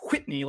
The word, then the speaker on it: Whitney: That's how you have to pronounce it. It's W-H, Whitney, like cool whip.